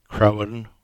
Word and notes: Crowan: English

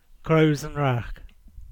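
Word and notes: Crows-an-Wra: Cornish

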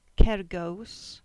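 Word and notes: Kergoos: Cornish

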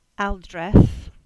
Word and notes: Aldreath: English

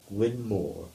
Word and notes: Gwynne Moor: English

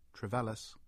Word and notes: Trevellas: English

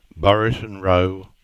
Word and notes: Buriton Row: English

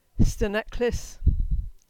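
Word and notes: Steneklys: Cornish

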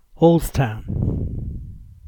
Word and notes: Halsetown: English

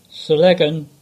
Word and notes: Sellegan: English

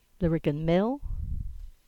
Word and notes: Lariggan Mill: English

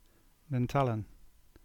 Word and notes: Nantellan: English

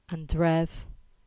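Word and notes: Drift: Cornish

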